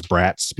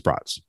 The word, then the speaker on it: brats: So, although I so desperately want to read it as brats because that's how it's spelled, but it's brats, bratwursts.